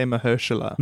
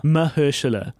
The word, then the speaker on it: Mahershala: How do you pronounce his name? Mahershala. – I say Mahershala. – Mahershala.